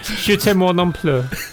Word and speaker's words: Je t'aime moi non plus: This is Je t'aime non plus. I didn't say that right at all, did I? How'd you say that? Je t'aime moi non plus.